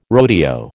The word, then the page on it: rodeo: American English